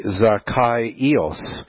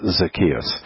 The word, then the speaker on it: Zacchaeus: And so, it's actually Zacchaeus is how you say his name. But I've always heard it just Zacchaeus.